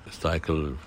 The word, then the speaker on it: Stycle: Stycle, da wird man jetzt Englisch aussprechen, die also aus Flaschen verschiedene Gegenstände, aus alten Weinflaschen halt machen.